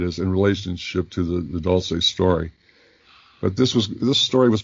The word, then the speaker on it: Dulce: Back to the Dulce, that's how you pronounce it, by the way, Dulce.